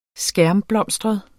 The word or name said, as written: skærmblomstret